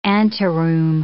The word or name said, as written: anteroom